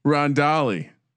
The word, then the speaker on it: Rondale: How do you pronounce it? – I don't know. Rinalde? – Rinalde. – No. – Who's Ronald Moore? Getting Rondale, in the chat. – Rondale. Rondale Moore.